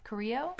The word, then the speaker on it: Carrillo: Carrillo. Spanish. – I can't see very far. – Don't pronounce the L's.